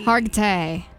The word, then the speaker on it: Hargitay: Mariska Hargitay. Hargitay. – Yeah. You got it right.